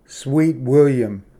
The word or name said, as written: ᎠᏂᏧᏣ ᎠᏂᏥᎸᏍᎩ